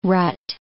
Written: rut

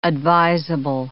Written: advisable

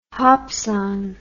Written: hopsan

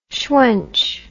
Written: shwench

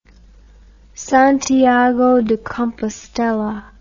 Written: Santiago de Compostela

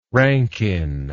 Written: rankin

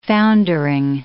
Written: foundering

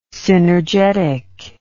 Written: synergetic

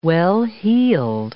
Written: well-heeled

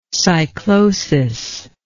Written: cyclosis

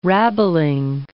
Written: rabbling

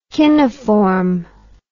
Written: chinoform

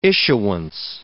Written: issuance

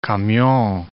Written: camion

